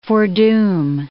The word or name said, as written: foredoom